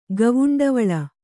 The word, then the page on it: ಗವುಂಡವಳ: Kannada